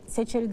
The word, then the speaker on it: Seçer: İşte Mansur Yavaş'ı da bırakmayız dedi, Zeydan Karalar'ı da dedi, Vahap Saçar'ı da dedi, Seçer'i de dedi.